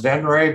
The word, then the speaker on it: VENREZ: It's pronounced VENREZ, but it's spelled VENREZ, V-E-N-R-E-Z.